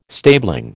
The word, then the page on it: stabling: American English